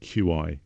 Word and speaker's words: Qi: And Qi is spelled QI for some reason, right. – But it's called Qi.